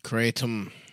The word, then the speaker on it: kratom: Kratom is pronounced K-R-A-Y-T-U-M. – Kratom. – I guess that's how you spell the pronunciation.